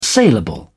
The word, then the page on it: saleable: British English